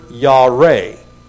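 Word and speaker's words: Yare (ירא): The fear that is mentioned right here is a Hebrew word, Yare, Y-A-R-E.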